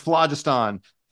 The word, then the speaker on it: phlogiston: It's pronounced Werner Vinge and it's pronounced phlogiston.